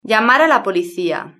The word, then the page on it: llamar a la policia: European Spanish